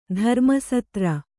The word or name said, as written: ಧರ್ಮಸೂತ್ರ